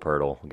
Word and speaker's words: Poeltl: It's because it rhymes with Wordle. That's the point. – Yeah, yeah. Wordle, Poeltl.